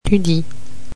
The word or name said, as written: tu dis